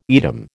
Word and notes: Edam: American English

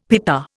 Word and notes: pita: Indian

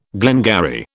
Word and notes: glengarry: British English

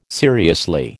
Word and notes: seriously: American English

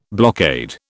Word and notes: blockade: British English

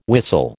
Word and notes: whistle: American English

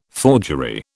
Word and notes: forgery: British English

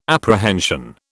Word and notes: apprehension: British English

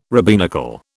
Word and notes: rabbinical: British English

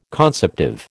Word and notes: conceptive: American English

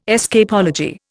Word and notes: escapology: Indian

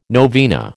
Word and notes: novena: American English

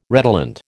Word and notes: redolent: American English

